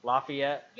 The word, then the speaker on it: Lafayette: Did I say that correct, dear? Lafayette? – Yes. – I said it right? – Yes. – Well, I know how you are, because I used to say Lafayette. – No, it's Lafayette. And, okay, so Lafayette.